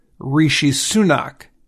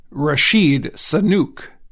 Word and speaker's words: Rishi Sunak: He had trouble saying the name of Great Britain's new prime minister, Rishi Sunak. Biden said Rashid Sunuk.